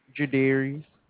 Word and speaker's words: Jerderius: Jadarius. – Could you spell that? – J-E-R-D-E-R-I-U-S.